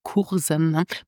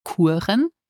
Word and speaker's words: Kuren: So this means that now I'm learning with online courses and pronounce courses in Luxembourgish Kuren and not Kursen, doch Kuren.